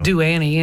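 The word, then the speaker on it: Ado Annie: Adu Annie, yeah.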